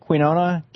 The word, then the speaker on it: quinoa: Is that how you say it? Quinoa? Q-U-I-N-O-A? Quinoa? Quinoa. Quinoa. Thank you.